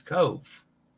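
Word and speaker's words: Cobh: The pronunciation of it's Cove. Yeah, that's correct.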